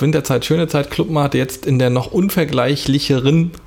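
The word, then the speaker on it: Club-Mate: Ah, weil es eben dann doch wahrscheinlich gewünscht ist, Englisch ausgesprochen zu werden. – Clubmate.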